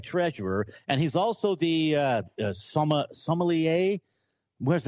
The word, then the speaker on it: sommelier: He's that sommelier. Am I saying that right, Julian? From Xanterra Glacier Lier. Park? – Sommelier. So sommelier comes from French. – Sommelier. Yes, it does.